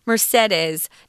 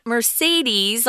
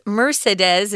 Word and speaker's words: Mercedes: And her name um, is and was back then Mercedes, not Mercedes like the car. This is Mercedes, is really how they pronounce it. It's a little different.